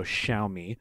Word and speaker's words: Xiaomi: She gave me, she's like an expert on how to pronounce it, so Xiaomi.